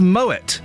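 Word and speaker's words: Moët: The French call it Moet as well.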